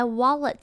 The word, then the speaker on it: wallet: 接下来 wallet 这个字中间的 a 要连着 l 发音，会有点像是 o 一样的声音，后面的 e 则是要发出像是 a 一样的声音，整个字念起来就像是 wallet wallet。